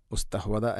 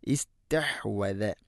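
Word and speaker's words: اسْتَحْوَذَ: ഇസ്തഹ്വദ, അവിടെ അസ്തഹ്വദ എന്നോ ഉസ്തഹ്വദ അല്ല, ഹംസക്ക് കസറാണ്. ഇസ്തഹ്വദ തൊണ്ടയുടെ മധ്യഭാഗമാണ്. ഇസ്തഹ്വസ അല്ല, ഇസ്തഹ്വദ.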